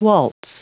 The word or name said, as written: waltz